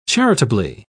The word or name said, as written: charitably